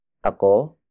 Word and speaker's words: ako: Word for word, it's nagsaing ako para kay Tita Sylvia. Nagsaing ako para kay Tita Sylvia.